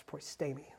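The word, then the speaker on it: proistemi (προΐστημι): The second type that we see is that proistemi, which I like because I didn't know exactly how to pronounce that one. I had to look it up and I got really good at pronouncing it. It's proistemi. Sounds great. Makes you sound very, very Bible-y.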